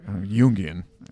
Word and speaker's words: Jungian: Jungian. It should be pronounced.